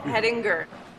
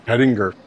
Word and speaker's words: Hettinger: Oh, Hettinger. – Hettinger.